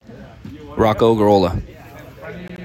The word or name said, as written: Rocco Gurrola